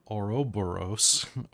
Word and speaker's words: Ouroboros: Blood is pouring between his teeth, and he raises his hand, and the Aurora, why can I never pronounce it on the first try? The Ouroboros.